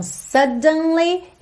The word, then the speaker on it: suddenly: Então, suddenly, ele faz esse D marcadinho, suddenly, não faz suddenly, suddenly.